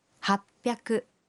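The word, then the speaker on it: happyaku (はっぴゃく): Y 800 se dice happyaku.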